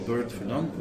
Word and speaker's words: Bert Verdonck: So just look on LinkedIn and type in my name, Bert Verdonk, which is B-E-R-T. V-E-R-D-O-N-C-K.